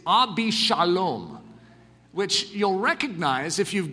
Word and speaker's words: Absalom: And so Ab Shalom, but it's pronounced Abishalom. Ab meaning father, Shalom, peace.